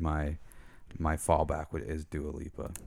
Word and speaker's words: Dua Lipa: Now, album of the year, I mean, I want to maybe say Taylor Swift, but maybe Dua Lipa. Did I say that right? – Dua Lipa.